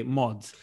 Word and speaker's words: Maud: Saint Maud, ou seja, eh, M-A-U-D, Mod.